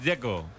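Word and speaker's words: Džeko: El que me parece difícil es el de Checo, Dzeko, porque de Z, ¿cómo se pronuncia? – Dzeko. Bruno dice que es Dzeko. – Como tirando a J, Dzeko o algo así.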